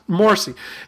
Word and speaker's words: Morsi: What's it, Morsi? How do you say his name? I don't know. – Morsi.